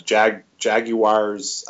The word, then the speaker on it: Jaguars: You sound like Gus Bradley pronouncing Jaguars. I can't even do it because I'm not from that part of the country. It's Jaguars.